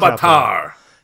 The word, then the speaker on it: Ulaanbaatar: You mean like Ulaanbaatar? How do you say that? – Ulaanbaatar.